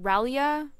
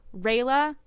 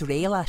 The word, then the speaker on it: Rayla: Is it Raylia? Raylia? – Rayla. – Okay, it's Rayla. My brain likes to add in different... – It's Rayla.